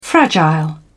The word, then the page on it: fragile: British English